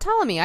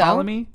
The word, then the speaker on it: Ptolemy: Ptolemy. I think so. – Ptolemy. You don't say the P? That's disappointing. – I think it's disappointing. – Because of the silent P.